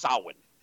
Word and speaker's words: Samhain: It's spelled Samhain. It's pronounced Samhain. – Yeah. – I got that drummed into me. It's like, no, that's not how, it's not phonetic.